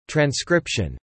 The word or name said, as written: transcription